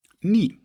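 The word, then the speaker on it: Ny: Es wird zumindest als griechischer Buchstabe ausgesprochen, habe ich im Internet gelesen. Das heißt nie. – Ah, okay. – Wie die Ritter, die immer nie sagen. Nie, nie, nie.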